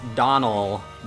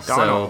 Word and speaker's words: Domhnall: They said that it's pronounced Donal, D-O-N-A-L, Donal. – So there you go. Donal Gleeson. – Okay, just a silent M-H, that's normal.